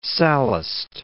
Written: Sallust

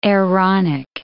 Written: Aaronic

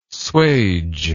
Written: swage

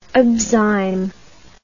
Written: abzyme

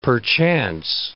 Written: perchance

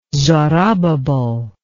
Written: zorobabel

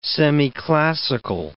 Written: semiclassical

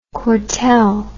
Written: Courtelle

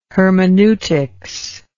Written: hermeneutics

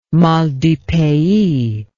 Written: mal du pays

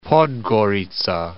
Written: Podgorica

